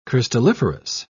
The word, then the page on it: crystalliferous: British English